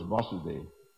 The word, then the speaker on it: Vasudeva: Vasudeva is Krishna, becomes a long A, his father is Vasudeva.